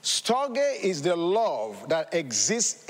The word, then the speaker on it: storge: The second one is storge. Now, of course, I'm not Greek. Maybe my pronunciation may not be correct. It's spelled S-T-O-R-G-E. Storge.